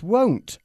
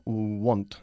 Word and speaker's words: won't: Y se dice won't y no want, ¿eh? – Won't. – Muchísimos españoles se equivocan a la hora de pronunciar esta contracción, won't, diciendo want, que como todos sabemos significa querer.